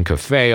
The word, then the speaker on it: cafe: At the cafe，大家看那个单字 C F E 哈，那个是借来的字哈，从外文借来的英文单字要念成 cafe 哦。